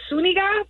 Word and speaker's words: Zuniga: The way it's pronounced is Zuniga, but if we're trying to help... – Like the actress Daphne Zuniga.